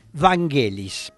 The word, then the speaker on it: Vangelis: Es más conocido con el nombre de Vangelis o Vangelis, pero yo me estuve asesorando con gente que conoce la pronunciación en griego y lo correcto es decir Vangelis.